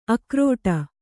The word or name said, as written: ಆಕ್ರೀಡೆ